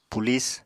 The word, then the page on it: pulis: apatani